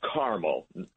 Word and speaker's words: Carmel: The pronunciation there is Carmel. – Carmel. – Unlike Carmel by the sea in California. – Right. Or Carmel that you eat.